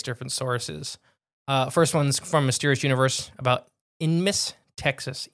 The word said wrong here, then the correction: Ennis: E-N-N-I-S. Enmis? – E-N-N what? – I-S. – Ennis. – Ennis? – Yeah. – How you say it? – Uh-huh. – Okay, like Dennis? – Yeah, without the D. Ennis.